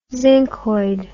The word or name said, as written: zincoid